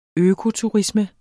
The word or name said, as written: økoturisme